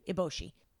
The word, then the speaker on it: Eboshi: Eboshi.